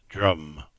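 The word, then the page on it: Drym: Cornish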